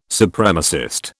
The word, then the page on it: Supremacist: British English